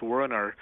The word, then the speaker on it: Werner: It's actually pretty close. My full name in English is pronounced Werner, and my last name is Oyanadel.